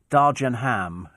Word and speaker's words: Dagenham: Dagenham is pronounced Dargenham in Lower Essex.